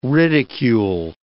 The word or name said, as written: ridicule